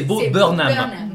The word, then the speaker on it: Bo Burnham: Tu l'as mal prononcé, je suis désolée, c'est Bo Burnham.